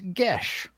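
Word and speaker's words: Geas: Technically, it's pronounced Gesh. – Gesh. – It's not how it's spelled.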